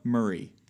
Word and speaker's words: Murray: Murray. – Yes, like Bill.